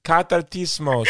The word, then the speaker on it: Katartismos: Aloha, Faith McDonnell from Cartatismos Global. Did I say that right? Cartatismos.